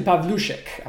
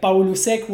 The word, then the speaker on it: Pavlushek: That would be like Paulusek would be the Argentinian way, quote unquote, to Okay.